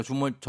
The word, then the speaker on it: 주말: 아니요, 주말 저는 주멸로 들었는데요. 아, 주말 주말.